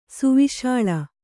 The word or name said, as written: ಸುವಿಶಾಳ